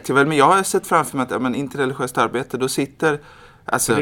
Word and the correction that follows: interreligiöst: det du säger nu är inte interreligiöst. – Bra, jag ska vara noga med betoningen, interreligiöst arbete.